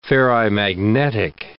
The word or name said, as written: ferrimagnetic